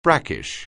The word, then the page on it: brackish: anglais